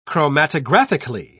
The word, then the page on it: chromatographically: British English